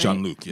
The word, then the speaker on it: Jean-Luc: Jean-Luc Picard. That's how you say his name, right? – Jean-Luc, yes. – Oh, it's not the French way. – Jean-Luc. – Jean-Luc. – I was trying to say it the Francais way.